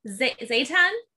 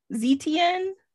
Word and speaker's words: Zetian: Zaytan? – No. So, like I said, I was using American English and I was pronouncing her name Zershian because the T-I-A-N is Shien, but it's like, it sounds French almost. Zetian? – Like a Zatar? – Huh? – Like Zatar? – No, like Zetian. – Zetian. Yeah, so if you say it like in French, maybe it'll work. – Huh?